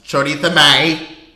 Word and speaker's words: Choriza May: So up next we have Choriza May, pronounced Chorita May.